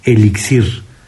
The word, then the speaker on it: elixir: Explica el diccionario panhispánico de dudas que la forma más común es elixir, sonando fuerte al final y se escribe sin acento esa palabra. Pero que en algunos países, sobre todo en América, se utiliza mucho la palabra elixir.